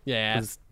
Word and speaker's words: Yass: Well, how do you say that? It's too Y. Yass. – Yass. – Y-A-S-S, right? – Mm-hmm. Yeah.